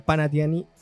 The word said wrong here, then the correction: Panathinaikos: ¿Cómo se dice eso? Panathinaikos.